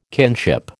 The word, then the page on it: kinship: American English